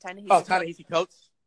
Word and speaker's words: Ta-Nehisi Coates: Ta-Nehisi. How do you pronounce that? – Ta-Nehisi Coates.